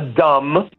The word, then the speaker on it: dumb: A Dumb, per il semplice fatto che c'è una bellissima B muta, non so se l'avete notato. – No, io non l'ho notato perché la pronuncio giustamente sbagliando, perché non me ne ero mai accorto. Cioè non si dice la B finale? – Meno male che l'ho detto.